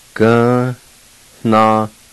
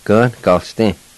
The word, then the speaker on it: Gnagalisti: Gnagalisti. And the I again with the L is silent. Gnagalisti.